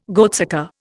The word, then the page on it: goatsucker: Indian